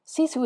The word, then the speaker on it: sisu: It's spelled S-I-S-U, and it's pronounced SISU.